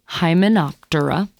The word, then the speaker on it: Hymenoptera: So the first one is Hymenoptera. By the way, Tura, P, there's a P in front of there. You just don't really pronounce it, you know, kind of like pterodactyl. So P-T-R-A means wing in Latin.